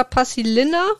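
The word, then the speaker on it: Paasilinna: Das Buch ist von Arthur Pasilina oder Pasilina wird dann ausgesprochen.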